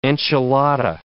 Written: enchilada

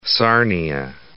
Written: Sarnia

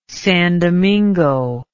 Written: san domingo

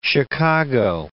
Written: Chicago

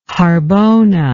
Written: harbona

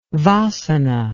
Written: vasana

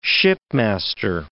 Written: shipmaster

